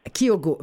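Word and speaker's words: Keeogo: Kyogo aussi, est-ce que je le prononce bien, donc, qui est le nom de cet appareil d'assistance dont nous allons parler aujourd'hui. C'est bien ça? – Oui, Kyogo, oui.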